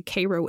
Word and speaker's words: Cairo: which caused damage and death from Pittsburgh to Cairo, Illinois. And just as an aside here, y'all, if you're unfamiliar with Cairo, it's spelled like Cairo in Egypt, but pronounced the way that I said it.